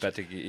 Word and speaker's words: Lopetegui: I'm not sure how much Julen Lopetegui, whose name I still can't really pronounce. – Lopetegui, yeah.